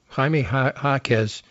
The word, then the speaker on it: Jaime Jaquez: Now, the key to that game is going to be the health of Jaime Huizang. Is that how you say it? – No. Jaime Jaquez.